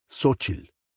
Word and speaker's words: Xochitl: It isn't pronounced as it's written. We say Xochitl, and the language is Nahua, not Nahuatl. The name Xochitl appears only a few times in the novel, but the emperor Axayacatl comes up constantly.